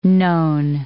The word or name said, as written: known